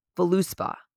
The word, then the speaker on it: Voluspa: Voluspa. It's not Voluspa. It's like Voluspa. V-O-L-U-S-P-A.